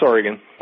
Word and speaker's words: Oregon: Is it pronounced Oregon or Oregon? – No, it's Oregon.